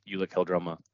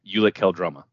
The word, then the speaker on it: Ulic Qel-Droma: Before we jump into Mando, I do, from my understanding, you pronounced Ulic Qel-Droma correctly.